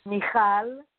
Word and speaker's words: Michal: Michal Nachmany.